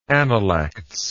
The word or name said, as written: analectic